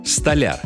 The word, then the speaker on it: столяр: Одно из них – столяр. Да, говорить нужно именно так, с ударением на последний слог.